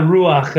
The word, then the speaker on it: ruach: Did I pronounce that right, though? How do you pronounce it? – Yeah, ruach. The hovering. – There you ruach. – Go. I said ruach, like CK.